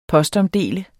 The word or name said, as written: POSTOMDELE